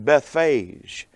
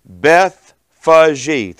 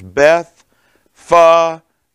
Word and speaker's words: Bethphage: I've always pronounced it Bethphage. That's what it looks like to me. But the proper pronunciation is Bethphage. Three syllables. Bethphage.